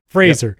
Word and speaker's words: Fraser: You say it like a Z. Fraser.